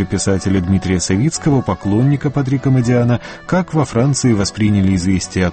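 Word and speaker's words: Модиано: Во Франции, разумеется, ставят ударение на последний слог, но Модиана – итальянская фамилия.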